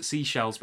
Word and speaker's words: Seychelles: Seychelles by the Seychelles.